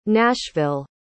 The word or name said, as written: nashville